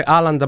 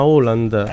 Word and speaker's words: Haaland: Sono venuto a spiegargli che non si dice Haaland ma Haaland.